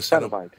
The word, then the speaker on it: Cenobite: A Cenobite? Is that how you say it? – Yes. – A Cenobite.